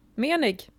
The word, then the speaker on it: Moening: Menig. – Kate Moening. – Menig. – Jaha, okej. – Okay. Det står till och med på hennes Instagram hur man ska uttala det. – Menig.